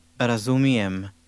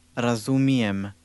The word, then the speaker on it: razumijem: Razumijem. Jem. Mijem. Mijem. Ra. Razumijem. – Did you hear the M sound at the end of that?